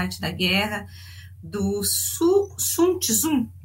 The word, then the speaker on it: Sun Tzu: Só Sun que no, origi no, no original chinês a gente fala Sun Tzu.